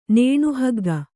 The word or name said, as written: ನೇಣುಹಗ್ಗ